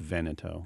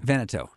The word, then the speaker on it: Veneto: So I have to tell you, it's pronounced Veneto. – Veneto.